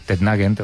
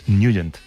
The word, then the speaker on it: Nugent: Hombre, siempre lo hemos llamado en el barrio Tednagent o Tednagen. – Claro, yo era de Tednagent. – Pero la pronunciación correcta es Nugent.